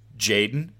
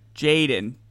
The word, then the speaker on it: jxdn: uh jxdn how's that actually ser- pronounced jaden jaden okay of